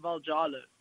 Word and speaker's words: Valjalo: Yeah, so normally most people don't pronounce the J, and they would in competitions, especially in American competitions, always pronounce my surname Valjalo.